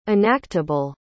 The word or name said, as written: enactable